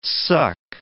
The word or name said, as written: suck